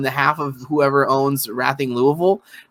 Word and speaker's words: Racing: Uh, Rathing is like the Spanish word racing in those club names. is it's pronounced because Spanish people, you know, like say Barcelona, like Barcelona. You have to like pronounce your C's like a TH.